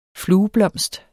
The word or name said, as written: flueblomst